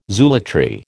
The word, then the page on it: zoolatry: American English